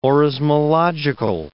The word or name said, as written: orismological